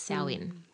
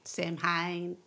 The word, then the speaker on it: Samhain: So, Halloween's origins date back to the ancient Celtic festival of Samhain, but it's spelt like the word Samhain, S-A-M-H-A-I-N, but it's pronounced Samhain. – Samhain.